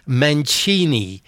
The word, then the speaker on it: Mancini: I came across a street named Via Henry Mancini, pronounced Mancini, in a small town called Scano in Abruzzo, Italy.